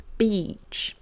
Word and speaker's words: beach: Mon i long, ça va être mon ea, beach. Petit aparté, le son ea ne se prononce pas toujours i.